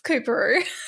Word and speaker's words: Coorparoo: It's Cooperoo. Corporoo. – Cooperoo? – Yeah. – Have they misspelled it or is there an R? No, you've said it wrong. – It's spelled C-O-O-R-P-A-R-O-O and it's pronounced Cooperoo? – Yeah, Cooperoo, Coops.